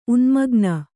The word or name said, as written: ಉನ್ಮಗ್ನ